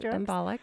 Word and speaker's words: embolic: So not to go into too much detail, but I have heard of thrombotic and embolic. Am I saying that right? Yeah, you're saying it, embolic.